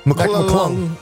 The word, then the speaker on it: McClung: Am I saying it right? – McClung. Yeah, McClung.